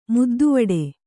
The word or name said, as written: ಮುದ್ದುವಡೆ